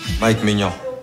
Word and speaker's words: Mike Maignan: Mike Maignan.